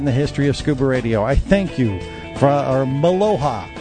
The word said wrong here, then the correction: Mahalo: Did I say it right? – Mahalo. – Mahalo. Okay, I just told him to go to take a jump in a lake or something in Hawaiian.